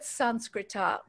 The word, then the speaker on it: Sanskrita: The word Sanskrita, which is the Sanskrit word for Sanskrit, Sanskrit, the way we're saying Sanskrit is really the Hindi pronunciation, takes the vowel off the end.